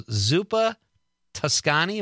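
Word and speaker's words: Zuppa Toscana: And then Robert on the stream says, This is Craig Anthony Kinnett says, also Zuppa Toscani. Am I saying that right? – Uh-huh.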